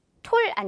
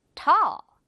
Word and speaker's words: tall: 예를 들어서, 키가 큰 하면, 톨 아니고, tall입니다. tall.